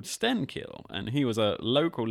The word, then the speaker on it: Stenkil: So enter Stenkil, or Stenskil, as he was probably more properly pronounced in Swedish and back in the day.